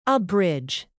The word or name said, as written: abridge